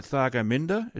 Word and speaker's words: Thargomindah: All right, look, I've got to finish up here, Jacinta, but, by the way, did I pronounce that right? Thargomindah is where you're from? – Yeah.